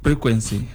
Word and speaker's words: frequency: Oh, you got frequency. – Frequency. – Frequency, yeah. – With P or with F? – F. Frequency, yeah.